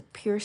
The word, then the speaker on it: PUR: There's the Pure system, P-U-R, or PUR. You may want to pronunciate it as to remember the spelling, but Pure is awesome.